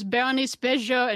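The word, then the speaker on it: Bérénice Bejo: And Miller, James Cromwell, John Goodman, Bernice Bejo, uh, and Jean. Oh boy, how do you say that? – It was Berenice Bejo and Jean Dujardin.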